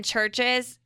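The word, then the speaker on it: CHVRCHES: So if you're looking this up and you type in Churches, it's spelt with it's completely different. – Not a U. Yeah. It's C-H-V-R-C-H-E-S.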